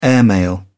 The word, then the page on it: airmail: British English